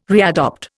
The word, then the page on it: readopt: Indian